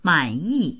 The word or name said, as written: man3yi4